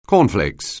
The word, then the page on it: cornflakes: anglais